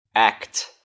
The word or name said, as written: act